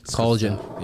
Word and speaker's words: collagen: The Calogen? Calogen? Yeah. Calogen production? Whatever. I don't know. – Collagen. – Yeah, there you go.